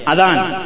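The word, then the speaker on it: അദാൻ (أذان): അദാൻ ചിലർ അസാൻ എന്ന് പറയും. അതാ ഭാഷാ ഉച്ചാരണത്തിലുള്ള പ്രത്യേകത മാത്രമാണ് നമ്മൾ കണക്കാക്കേണ്ടത്.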